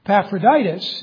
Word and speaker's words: Epaphroditus: Probably should be pronounced the latter way, because the emphasis should be on the last syllable in Greek, but I like the English way, Epaphroditus.